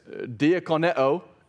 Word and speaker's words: diakoneo: It's pronounced like this, diakoneo and diakonia.